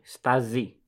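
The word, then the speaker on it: Stasi: Μια μικρή διόρθωση. Στάζι. – Στάζι. – Όχι στάση, ναι. Δεν στάση. Στάζι. – Στάζι. – Δεν ξέρω γιατί γράφεται στάση, διαβάζεται με ζήτα όμως.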